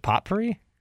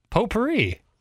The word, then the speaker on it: potpourri: Is it potpourri? – Oh, no. – Potpourri. Potpourri. I leaned into a T there. – Well, it's a silent.